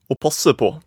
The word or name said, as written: aa passe paa